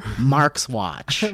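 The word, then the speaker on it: Marxuach: We finally found out how to say it correctly, and we all say it like this. Javier Grillo Marxwatch.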